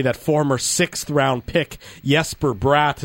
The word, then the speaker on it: Jesper: but he has decided that he is Jesper.